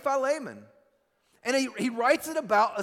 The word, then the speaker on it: Philemon: This is probably, I don't hear about it very often in churches, but Paul's last letter, the shortest letter that he writes, it's called Philemon, or Philemon, or Philemon, whatever you guys pronounce it as. I've heard it like five different pronunciations. My Greek professor said Philemon, so that's how I pronounce it.